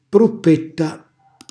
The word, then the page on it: pruppetta: calabrese